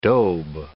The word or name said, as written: daube